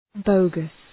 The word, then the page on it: bogus: ‘bəʋgəs